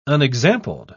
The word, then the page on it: unexampled: British English